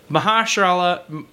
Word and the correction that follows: Mahershala: Mahershala Ali um, is like the market is totally saturated with him now.